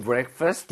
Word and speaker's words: breakfast: Did you have breakfast? – Did you have breakfast? 아직도 이거 너무 힘들어하는 거 같아요. – 제가 제일 어려워하는 발음이에요. – 그렇죠. Break, 그러니까 break이라고 하지 마시고 거의 break.